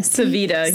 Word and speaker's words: Civita: Civita, yeah. – C-I-V-I-T-A.